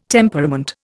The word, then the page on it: temperament: Indian